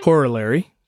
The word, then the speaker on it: Corollary: And most of us know Horseman's Corollary. Did I say that right? – Corollary. – Thank you.